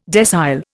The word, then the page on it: decile: Indian